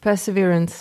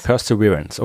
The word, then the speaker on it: Perseverance: Perseverance. – Perseverance, okay.